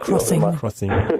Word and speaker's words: Xing: Ja, sicher, aber also ich finde, ein ganz schönes Beispiel ist ja Xing zum Beispiel oder wie spricht man es jetzt aus? – Crossing.